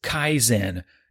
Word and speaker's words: Kaizen: All right, word number two is Kaizen, and that is spelled K-A-I-Z-E-N.